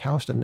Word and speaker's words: Houston: So for out-of-towners, there's an east-west thoroughfare, a very long east-west street in Manhattan called H-O-U-S-T-O-N. And we say it New York, Houston. And in Texas, they have this odd pronunciation of the same word. Like what is it, Houston? – Yeah, strange.